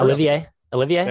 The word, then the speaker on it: Olivier: Oliver Kamwa. If I get that pronunciation correct, one of my few. – I actually got the one right. – Pretty good. – Yeah. But not the first. – Olivier? – There you go.